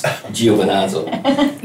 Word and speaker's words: Giovinazzo: I can't pronounce Giovinazzo.